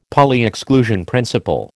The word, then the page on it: Pauli exclusion principle: American English